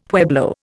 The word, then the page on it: pueblo: Indian